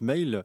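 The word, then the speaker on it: M(h)aol: Alors du groupe, ça se prononce Mail, et alors ça s'écrit M-H, le H entre parenthèses A-O-L.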